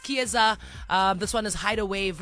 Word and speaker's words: Kiesza: K-I-E-S-Z-A. It's Chiesa today.